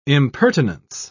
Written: impertinence

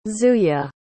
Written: zuya

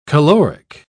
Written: caloric

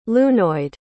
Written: lunoid

